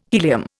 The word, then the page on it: helium: Indian